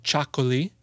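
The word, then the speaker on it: txakoli: It's called Chacoli. It's spelled funny. It's T-X-A-K-O-L-I, I think, something like that.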